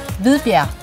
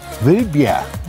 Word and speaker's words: Hvidbjerg: Hvidbjerg. – Hvidbjerg.